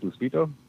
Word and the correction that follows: Lufisto: Lufisto. – Lufisto. Yeah, that's it.